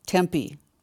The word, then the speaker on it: Tempe: A neighborhood in Tempo, is that how you say it? I don't know. Arizona, huh? – Tempe. – Thank you for the correction.